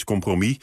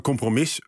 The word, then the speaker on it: compromis: Die S die spreek je dan weer niet uit. Tenminste, hè, dus in het enkelvoud is het de gewone uitspraak is compromis. Maar compromis kan ook. Die uitspraak komt ook wel voor in het enkelvoud. Dit zijn allebei wel geaccepteerde varianten.